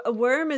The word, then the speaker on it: wyrm: Worm is one of those words that has, and it's, in Old English, it's spelled W-Y-R-M, so worm.